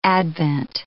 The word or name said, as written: advent